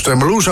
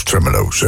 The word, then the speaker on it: Tremeloes: The Bad Times Are Good van de Tremeloes. De Tremeloes zag ik vroeger altijd. En ik dacht van, oké, dan moet je zeker als Tremeloes uitspreken. Maar het was dus Tremeloes.